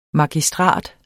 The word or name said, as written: MAGISTRAT